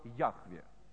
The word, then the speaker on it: Яхве: То когда имя не упоминается и говорится ради имени, существует только одно имя – Яхве, то есть Святой, Яхова. Но правильное звучание этого слова – Яхве.